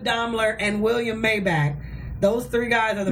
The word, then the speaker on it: Maybach: It's Maybach.